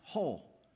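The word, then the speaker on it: ho (ὁ): It's pronounced ho in the Greek, and it's always translated into English the word the.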